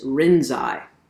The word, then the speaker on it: Rinzai: Now, the Japanese pronunciation of Linji's name is Rinzai.